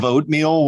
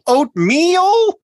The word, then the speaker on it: oatmeal: And what's with the weird pronunciation of oatmeal where like all the emphasis is on meal? Oatmeal? – Oatmeal.